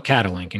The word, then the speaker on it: Catalink: Is that's how, am I saying it properly? And what is the name? – That is right. So this capability that we've been talking about potentially building is, we call it Catalink.